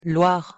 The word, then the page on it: loire: French